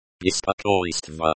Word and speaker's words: беспокойство: In the examples above, the stress is on the second syllable of беспокойство and беспокоит.